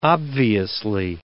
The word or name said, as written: obviously